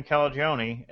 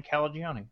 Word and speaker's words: Calagione: But craft beer icon Sam Calagione, and we discovered we can just think Chuck Mangione to get his name right. All in the pre-show, we're like, how do you say his name? We're like, think Chuck Mangione. Like, oh, Sam Calagione.